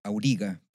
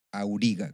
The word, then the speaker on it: Auriga: Auriga. – Palabra grave. Auriga, claro. Terminaba en vocal, por lo tanto no lleva tilde.